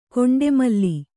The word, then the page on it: ಗಂಡಾಮಾಲೆ: gaṇḍāmāle